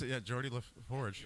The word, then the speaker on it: Geordi LaForge: Yeah, that's Geordi LaForge.